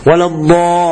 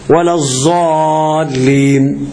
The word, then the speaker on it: الضالين: أدي معنى كلام شيخنا، ها، والتشديدات الأربعة عشرة، وإخراج الضاد من الظاء، يعني تنطق غير المغضوب عليهم، المغ ولا الضاء، ما تقولش ولا الظاء كلغة قوم، كلهجة عند قوم، ولا الظالين، ما ينفعش عندنا ولا الظالين، كان ينفع عند قوم ولا الظالين، فعندنا ما ينفعش ولا الظالين، اللي عندنا ينفع، ولا الضالين.